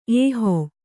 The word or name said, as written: ಏಹೊ